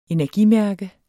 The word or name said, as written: energimærke